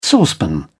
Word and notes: saucepan: British English

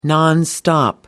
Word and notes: nonstop: British English

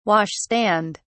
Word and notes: wash stand: British English

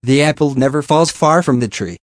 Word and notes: the apple never falls far from the tree: American English

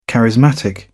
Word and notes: charismatic: British English

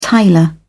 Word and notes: tiler: British English

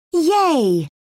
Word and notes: yay: British English

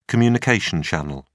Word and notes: communication channel: British English

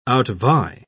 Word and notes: outvie: British English

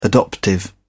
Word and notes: adoptive: British English

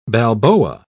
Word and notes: balboa: British English

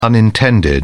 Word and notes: unintended: British English

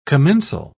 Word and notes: commensal: British English

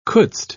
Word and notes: couldst: British English

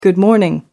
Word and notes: good morning: American English

